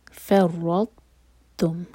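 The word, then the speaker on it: फ़र्रतुम (فرطتم): फ़र्रतुम, तो फ़र लाइट, रॉ हेवी, और रॉ को हम तो से जोड़ेंगे लेकिन तो को प्रोनाउंस नहीं करेंगे. फ़र्रतुम, आवाज़ निकलेगी तय की, तय लाइट लेटर है.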